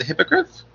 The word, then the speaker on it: hippogriff: and he talked bad to the hippogriff.